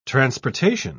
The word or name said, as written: transportation